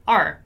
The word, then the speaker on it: our: But if I pronounce the word hour in the middle of a sentence and I'm saying it quite fast, the pronunciation is going to change a little bit. It's going to be just R, R, R, right?